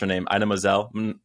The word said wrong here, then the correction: Idina Menzel: Oh, Idina Menzel.